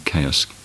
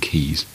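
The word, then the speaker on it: Caius: Two of our oldest universities, where some of this stuff is studied, as colleges often mispronounced. Magdalen College, Oxford, is actually Magdalen College, and Gonville and Chaos, Cambridge, is Gonville and Keys.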